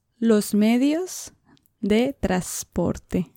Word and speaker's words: transporte: So the N is hardly pronounced. So we'd say like transport, they say almost like transporte.